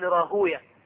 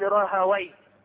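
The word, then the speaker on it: راهويه: ذهب ابن حزم وإسحاق بن راهوية، والمحدثون ينطقونها هكذا يقولون إسحاق بن راهوية، وغيرهم يقول إسحاق بن راهاوي.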